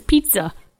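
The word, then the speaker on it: pizza (пицца): Do you know that pizza in Russian is pizza? That's how you say it.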